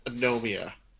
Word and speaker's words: Anomia: uh, A N O M I A. Anomia.